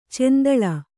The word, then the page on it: ಚೆಂದುಳ್ಳೆ: cenduḷḷe